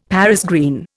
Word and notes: Paris green: Indian

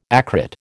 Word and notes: acrid: American English

